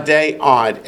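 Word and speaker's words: adei ad: Aday ad, A-D-E dash A-D. – A-D is dog. Alpha dog, right. A-D-E dash A-D.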